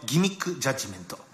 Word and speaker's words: 欺瞞裁判: さて、ここからは先週からスタートしたこちらの新コーナー、ザ・ナンバーロジック、数字のギミックジャッジメント。欺瞞裁判と書いてギミックジャッジメントって読ませるやつがこの世の中にいるんですよ。